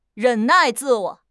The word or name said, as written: 忍耐